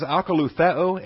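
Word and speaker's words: akalutheo (ἀκολουθέω): And this then becomes, the verb here, by the way, is akalutheo, A-K-O-L-O-U-T-H-E-O, akalutheo. You got a combination of omicrons and omegas there. The omicron is the short O, there and there. The omega is the long O. So it's A-K-O-L-O-U-T-H-E-O, akalutheo, to come after, to follow.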